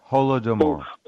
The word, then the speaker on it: Holodomor: Is that how you say it? Holodomor? – Yeah, Holodomor.